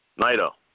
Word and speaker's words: Naito: is it Naito? Is that how you say it? – Naito, yeah.